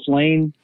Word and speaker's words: Lain: My name's Douglas Lane. My last name is spelled L-A-I-N.